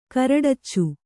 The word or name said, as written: ಕರಡಚ್ಚು